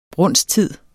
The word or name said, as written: brunsttid